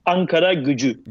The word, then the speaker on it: Ankara Gücü: O sea, nunca lo había, había escuchado pronunciado bien esto, pero a ver, ¿cómo es, esto? – Es Ankara Gücü, que literalmente sí, se traduciría como la fuerza o la potencia de Ankara.